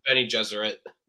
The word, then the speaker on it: Bene Gesserit: One of them is based on one of the Bene Jesuits. Am I saying that right? Bene Gesserit or Jesuit? Bene Gesserit.